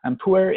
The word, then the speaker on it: Pu-erh: Puer tea, can you spell that puer? – Yeah, P-U-E-R-H, puer.